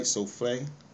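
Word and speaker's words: soufflé: Where she served a neat menu of simple Southern inspired dishes, including chocolate souffle. Did I say that right? Souffle? – Souffle.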